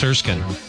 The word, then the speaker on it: Erskine: Erskine. Texts are saying Erskine. It looks like Erskine, but it's Erskine. Just spelled odd.